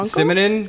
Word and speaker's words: Simonon: Well, but speaking of kind of a reggae rock-ish kind of thing, I want to shout out Paul Simonon from... – Okay. – The Clash. – Yeah. Okay. I thought you said Paul Simon and I was like Garfunkel. – Simonon. Oh, Simonon. Okay. – Yeah, S. Yeah. I know how to spell it. – S-I-M-N-O-N.